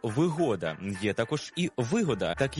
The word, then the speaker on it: вигода: Перше із них, що викликає у нас здивування – «вигода». «Вигода» має значення «зручність». Наприклад, «квартира з усіма вигодами», а не «вигодами». Це трискладовий іменник із наголосом на другому складі.